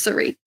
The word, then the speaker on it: Suri: The emphasis is on the last syllable. – Yeah, it's Suri.